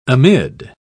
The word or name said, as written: amid